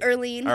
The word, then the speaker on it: Earlene: All right, come see Arlene at the, I keep calling you Arlene. Early. – It happens. – Oh, my gosh. Say your name for me. – Earlene.